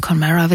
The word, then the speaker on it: Connemara: Die Seen des Connemara, denn Connemara wird eigentlich englisch ausgesprochen, ist eine mystisch aussehende Torfmoorlandschaft in Irland.